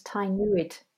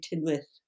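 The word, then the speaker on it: Tŷ Newydd: I thought it was Tynwyd. – It's spelled T-Y and then new word N-E-W-Y-D-D, but it's actually Tynwyth.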